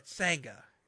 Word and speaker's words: Sangha: Vanga and Indashir. – Vanga? – Or Sangha. It's Sangha and Veer.